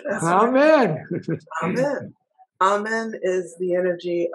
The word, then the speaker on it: Amen: How do you spell that name? – Amen. A M E N, the same way the Baptists.